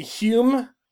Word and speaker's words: Hume: Okay, so 43 across is blank U-M-E. – 43 across, that Scottish guy you like. – That would be Hume, David Hume. If you'd wanted to be fancy, you could have gone with the old spelling, which was H-O-M-E, but still pronounced H-U-M-E.